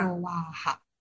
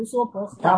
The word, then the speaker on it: 娑婆诃: 他说他那个婆在他那就读吧。 – 斯瓦哈，斯瓦哈的吧。我们这按照经现在的说法，就应该读娑婆诃。